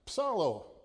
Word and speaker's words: psallo: And finally, we have the word, that's tough to pronounce, psallo, P-S-A-L-L-O, psallo. And it's, I sing a psalm. In English, the P is silent, thank goodness.